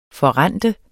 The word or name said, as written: forrente